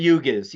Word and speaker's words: yugas: Is it yugas? Am I saying that right? – The yugas.